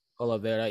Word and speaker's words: Oliveira: Oliveria, I think it's Oliveria or Oliveria. I'm not Spanish, so I would not have pronounced it. – Oliveira, yeah.